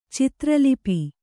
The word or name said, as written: ಚಿತ್ರಲಿಪಿ